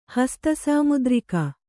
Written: ಹಸ್ತಸಾಮುದ್ರಿಕ

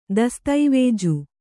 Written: ದಸ್ತಾವೇಜು

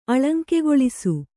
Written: ಅಳಂಕೆಗೊಳಿಸು

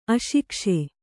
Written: ಅಶಿಕ್ಷೆ

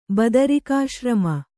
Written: ಬದರಿಕಾಶ್ರಮ

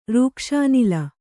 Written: ರೂಕ್ಷಾನಿಲ

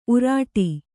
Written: ಊರಟೆ

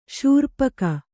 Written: ಶೂರ್ಪಕ

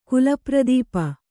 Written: ಕುಲಪ್ರದೀಪ

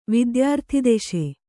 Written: ವಿದ್ಯಾರ್ಥಿದೆಶೆ

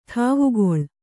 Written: ಠಾವುಗೊಳ್

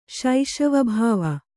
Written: ಶೈಶವಭಾವ

